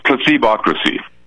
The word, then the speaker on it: Placebocracy: It's placebocracy.